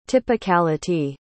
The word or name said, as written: typicality